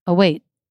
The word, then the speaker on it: await: You don't actually hear a T sound. There's no T sound happening. What's happening is the quality of the syllable is changing. Away. My voice goes, at the end. Await. It doesn't. It gets cut off. The sound stops.